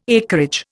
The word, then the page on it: acreage: Indian